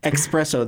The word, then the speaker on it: espresso: I hate it when people say espresso. I don't like it when people say espresso. Espresso. There's no X in espresso.